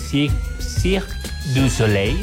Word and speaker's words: Cirque du Soleil: Y además, si tú eres fanático, pero fanático, así fanático del Cirque du Soleil, ¿sí lo dije bien? – Yes.